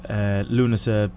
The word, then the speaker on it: Lughnasa: there's another kind of spelling, which is L-U-G-H-N-A-S-A, and that's Lunasa, pronounced the same, but it's more associated with a kind of a pre-pagan type festival that used to take place many moons ago type thing.